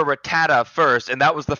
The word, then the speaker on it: Rattata: Also, how do you say it? I've said it different ways my entire life, and I haven't figured out the right pronunciation. – I pronounce it Rattata, and I use my American-given ego to assume that everybody else is wrong.